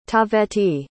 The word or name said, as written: taaveti